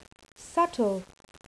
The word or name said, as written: subtle